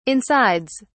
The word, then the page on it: insides: British English